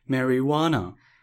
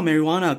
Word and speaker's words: marijuana: 所以大麻这个字是 marijuana，拼一下给大家听，M A R I J U A N A。可是我不知道 marijuana 跟它的这个历史有关诶。 – 有，为什么 J 会是反？它是叫 marijuana，为什么它是用墨西哥就是西班牙文的发音去哦去发这个字？